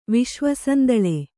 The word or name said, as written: ವಿಶ್ವಸಂದಳೆ